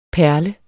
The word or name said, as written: PERLEDE